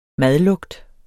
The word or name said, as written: madlugt